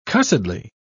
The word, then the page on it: cussedly: British English